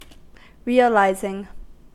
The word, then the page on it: realizing: American English